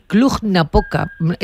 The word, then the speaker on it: Cluj-Napoca: Porque estáis rodando en Bucarest y Cluj-Napoca.